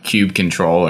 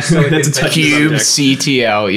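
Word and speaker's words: kubectl: Well, I did hear that Cube Control, or however your preferred way of saying that word, Cube CTL. Yes. I did hear that pronounced as Cube Cattle this week, which is taking that to a whole nother level.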